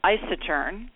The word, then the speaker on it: Isetern: It's spelled like I Saturn, but it's pronounced Isetern.